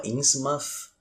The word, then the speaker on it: Innsmouth: É, eu não sei se é a cidade de Innsmouth, mas é, se escreve Innsmouth, mas aparentemente se fala Innsmouth.